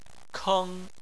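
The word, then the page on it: keng: tone 1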